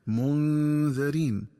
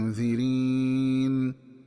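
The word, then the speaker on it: مُنذِرِين: فأما الكلمة الأولى فهي منذرين، فتقرأ بكسر الذال هكذا منذرين، ولقد أرسلنا فيهم منذرين. ومنذرين جمع منذر، وهو اسم فاعل من الفعل أنذر، ومن الخطأ قراءتها بفتح الذال هكذا منذرين.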